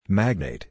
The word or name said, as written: magnate